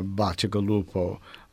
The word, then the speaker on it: Bacigalupo: The pronunciation is such that uh, it should be written Baciacalupo. Uh, it should be a name with two C's in it. But the second C is so often, especially in Sicilian dialect, the second C is pronounced uh, as a G, so that over the years, with all the Italian immigrants who've come to Boston, Calupo becomes Galupo, And in the phone directories in, in Boston, you'll see as many Galupos as you see Calupos.